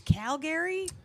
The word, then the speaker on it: Calgary: Yeah, it's pronounced Calgary. – Yeah, Calgary. – Yeah, if you're from Alberta, you say it's Calgary.